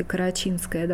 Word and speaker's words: карачинское: То же самое произошло с названием «карачинское» или «карачинское». Ударение все-таки «карачинское» должно быть.